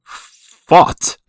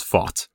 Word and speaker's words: Fought: Fought. Yeah. Huh. Yeah, it's spelled weird, but his name is Fought, essentially.